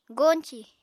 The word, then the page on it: goñcì: Apatani